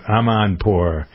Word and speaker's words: Amanpour: Every once in a while when I'm going to sleep, I turn on PBS and watch Amanpour. That's how you pronounce her name.